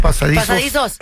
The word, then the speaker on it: pasadizos: Hay signos, hay imágenes, hay pasadijos. ¿Cómo se dice? Pasadizos.